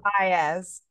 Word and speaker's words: Baez: so next up i have tell me how to pronounce it is it bays l baez baez okay cool yeah.